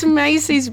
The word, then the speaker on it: Macy: I think it rhymes with Spacey. – Or Lacey. – Stacey. – Pasty at a stretch. It is, of course, Macy's brand new album, Once on CD.